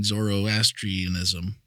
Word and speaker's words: Zoroastrianism: And I mean, this, you know, I'm no theologian, and, you know, I have a hard time pronouncing Zoroastrianism. But you did really good right there. There you go. That was right on the button.